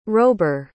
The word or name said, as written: rober